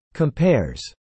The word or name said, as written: compares